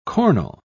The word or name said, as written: cornel